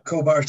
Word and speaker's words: Khobar: Khobar Towers. Am I saying that correctly? Khobar Towers? – Yeah, Khobar Towers.